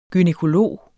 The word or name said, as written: gynækolog